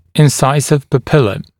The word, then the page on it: incisive papilla: [ɪn’saɪsɪv pə’pɪlə]; [ин’сайсив пэ’пилэ]